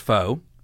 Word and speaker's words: pho: It's spelled P-H-O, but it's not pronounced pho, it's pronounced pho.